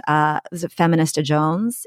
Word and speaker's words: Feminista Jones: And you cite Feminista Jones in your, am I pronouncing that right? – Yep.